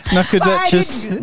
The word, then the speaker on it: Nacogdoches: I was finding it difficult to pronounce this. – In where? – Nacogdoches, Texas. – Are you sure Ian fixed those teeth? – Correct me if I'm wrong. – Nacogdoches in Texas. Yeah. Okay.